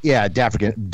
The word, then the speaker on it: Dafrican: So if people are looking for this, Angels is A-N-G-E-L-Z. And it's correct. – Featuring, are you saying it's Dafrican? Is that how it's pronounced? – Dafrican. D-apostrophe A-F-R-I-C-A-N.